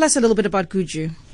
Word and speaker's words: Gudgu: And it's pronounced Gudgu.